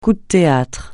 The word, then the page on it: coup de théâtre: French